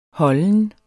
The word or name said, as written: holden